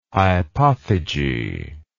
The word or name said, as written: hypophyge